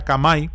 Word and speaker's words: Khmer: 20,000 copias de esta Biblia, impresas en Japón en el idioma Khmer, pero se pronuncia Kamai.